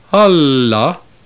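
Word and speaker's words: alla (ἄλλα): For example, the Greek word alla, accented on the first syllable, means other things, while the word alla, accented on the second syllable, means but.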